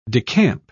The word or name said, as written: decamp